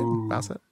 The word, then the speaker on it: Bowsette: So if you have the Switch, check that out, and you can't be Bowsette. Did I say that right? – Bowsette.